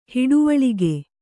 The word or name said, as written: ಹಿಡುವಳಿಗೆ